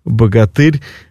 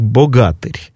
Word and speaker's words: богатырь: И, да, ну, точнее, «бог» — корень, да, а от суффикс «богатый», тот, который имеет «бога», «богатырь». Кстати, правильнее в былинном ритме это должно произноситься как «богатырь».